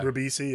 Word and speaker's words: Ribisi: Giovanni, is it Ribisi? Is that how you pronounce it? – Yeah. – Ribisi, yeah.